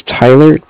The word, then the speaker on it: Tyler: My name is Tyler, T-Y-L-E-R. – And you're from? – And I'm calling with Cardiff. It's spelled C-A-R-D-I-F-F, as in Frank.